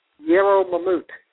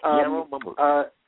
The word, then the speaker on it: Yarrow Mamout: And can you tell us a little more about the main tag, the Yarrow Mamout? Is that how you pronounce it? – Yarrow Mamout. He was Yarrow Mamout. – Yes, he was Fulani, F-U-L-A-N-I, from West Africa.